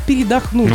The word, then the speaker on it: передохнуть: Но на самом деле это просто сигнал, что вам пора передохнуть. – Вот. – Пожалуйста, выделите сегодня время. Передохнуть, главное верно сделать ударение. – Да, не надо вот на «о».